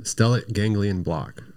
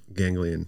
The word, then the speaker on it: stellate ganglion block: And how do you say that again? – Stellate ganglion block. – Okay, say it again. – Stellate. Ganglion.